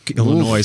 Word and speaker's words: Illinois: All right, well, let's head to Aurora, Illinois. – Ooh. – Illinois. Oof. I just pronounced the S at the end of Illinois.